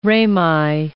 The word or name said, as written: rami